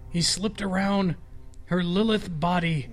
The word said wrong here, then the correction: lithe: Not how you say it. It's lithe.